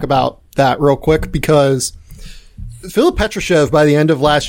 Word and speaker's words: Filip Petrushev: And you have a big in Filip Petrushev. Did I say that right? – Filip Petrushev. – He played on our circuit.